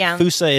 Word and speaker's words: fossa: F-O-S-S-A. Mm-hmm. And actually, some people do pronounce it fossa. – Some do, but fusa is the more accepted,